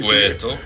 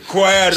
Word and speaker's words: Cueto: No, el que a mí más me gusta cuando ellos pronuncian el de Cueto, que debe de ser un apellido suave. – Cueto. – Cueto. – Y entonces el problema suyo es que usted lo dice como ellos. – Ellos dicen que Johnny Cuero.